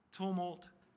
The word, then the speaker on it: tumult: T-U-M-U-L-T. Tumult. The emphasis is on the wrong syllable.